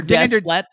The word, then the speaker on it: Desplat: I said it the French way. Alexandre Desplat. What? How do you say it? Wow. Alexandre Desplat.